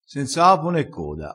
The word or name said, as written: senza apo ne coda